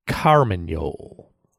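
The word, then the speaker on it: carmagnole: Carmagnol. – Carmagnol.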